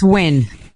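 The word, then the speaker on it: Nguyen: When is the first time that Christine Nijin went nude? Thank you. – All right, I think he pronounced it's Nijin, right? – It's Win. Like yeah. I won the Summer Olympics. Win.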